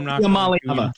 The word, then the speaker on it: Iamaleava: Nico Iamaleava. – Try. Iamaleava.